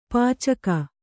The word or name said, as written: ಪೈಚುಕೆ